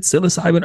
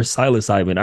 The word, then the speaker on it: psilocybin: Is it psilocybin or psilocybin? I heard you. I'm like, let me make sure I'm saying it correctly. Have I been saying it wrong? – No, man, it's either. It's kind of like tomato, tomato.